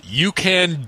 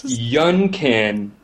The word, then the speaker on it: Yun Can: You're saying it wrong. You were saying it wrong, though. It's Yun Can Doo-Doo, or Dodo. – It says you can do. – Y-U-N-C-A-N, like Duncan, but with Yunkin.